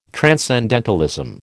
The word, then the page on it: transcendentalism: American English